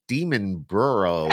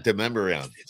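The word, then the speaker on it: Demonbreun: go, oh, this is Demon Burrow, or, yeah. Not Demumbrian. So Demumbrian. We actually had a friend that came down that pronounced it like that, and she's like, I don't know if I want to go down this street.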